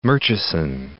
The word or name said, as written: Murchison